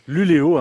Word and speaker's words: Luleå: Alors ça s'écrit Luleå, mais ça se dit Luleå. Hein, parce vous êtes totalement bilingue, vous. – Non, il y a le petit umlaut là, sur le A.